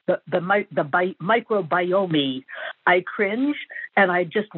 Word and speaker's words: microbiome: If he listens to public radio, he will hear multiple other shows pronouncing the word correctly. Microbiome. Biome is something that doesn't exist. It's the microbiome. That last E is silent.